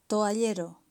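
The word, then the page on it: toallero: Spanish (Spain)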